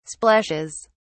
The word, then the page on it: splashes: British English